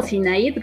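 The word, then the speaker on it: Synayd: En YouTube estoy como Sinaid Rubio, así como se escribe mi nombre, se los deletreo, es S y latina N A y latina D de dedo, eh, Rubio, y ahí me encuentran.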